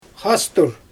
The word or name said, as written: xastur